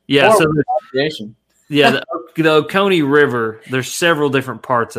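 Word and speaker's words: Ocoee: Owasi? What is it, the one that's below? It's like 30 minutes south of me. I'm in Cleveland right now. – Oh, Oconee.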